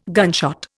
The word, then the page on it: gunshot: Indian